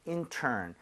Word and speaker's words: intern: I-N-T-E-R-N. 아까 전에는 그냥 넘어갔지만 얘는 발음을 꼭 건드려야 돼요. 중간에 R 있기 때문에 그냥 인턴은 안 되겠죠? – 네. – 혀가 뒤로 당겨져서 R 사운드를 낼 기회는 줘야 되는 거 아니에요?